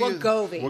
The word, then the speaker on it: Wegovy: Wegovy. – Wegovy.